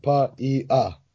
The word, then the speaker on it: Paiai: You just say all the vowel sounds one at a time. Paiai. – Paiai.